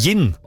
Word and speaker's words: jinn: Nei, ja, det skrives med jod. J-I-N-N. – Oh. – Gin. Men okay. Også det uttales gin. For på engelsk skrives det med D først. Gin.